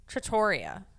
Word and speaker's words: Trattoria: So some people say Trattoria Al Forno. – Right. – But the actual pronunciation is Trattoria.